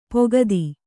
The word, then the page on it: ಪುಗುತೆ: pugute; Kannada